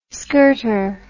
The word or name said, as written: skirter